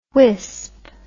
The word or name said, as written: whisp